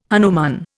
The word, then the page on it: hanuman: Indian